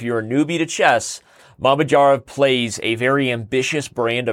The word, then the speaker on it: Mamedyarov: You added some wrong emphasis on the wrong syllables. So it's Shakir, Mamed Yarov. It's literally how it's Mamed Yarov.